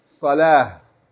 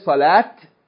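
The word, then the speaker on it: Salah (صلاة): That's ta mabuta on the end. But we don't pronounce the T. We don't say Zakat. Huh? We don't say Salat. Huh? Alaykum As-salam wa rahmatullah. Okay? We don't say Zakat. We don't say Salat. Because that ta marbuta, if you stop on it, you don't pronounce like T. You pronounce like Ha.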